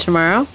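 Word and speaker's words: Tomaro: I am your host, Deb Tomorrow. That's T-O-M-A-R-O.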